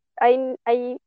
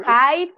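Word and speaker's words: Hye: o sea, yo le digo Hay, pero creo que sí se pronuncia Hey.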